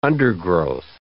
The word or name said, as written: undergrowth